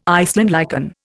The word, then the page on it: Iceland lichen: Indian